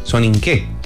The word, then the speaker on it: Soninke: Soninke, así se pronuncia.